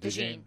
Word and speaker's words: DeJean: You would think Maybe there's a team that falls in love with Cooper DeJean, which is that how you pronounce it? DeJean. – Yeah, DeJean. Okay. Yeah, so very much a possibility of that.